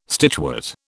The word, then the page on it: stitchwort: British English